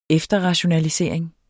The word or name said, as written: efterrationalisering